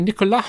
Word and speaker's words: Nicolas: Although apparently Nicolas Flamel. – Is that really? – It's a silent S, according to the trailer. It's like Voldemort. We'd all been saying Voldemort wrong for years.